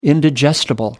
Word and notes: indigestible: American English

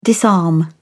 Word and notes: disarm: British English

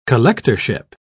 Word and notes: collectorship: British English

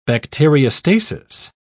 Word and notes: bacteriostasis: British English

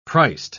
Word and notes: priced: British English

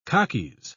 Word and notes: khakis: British English

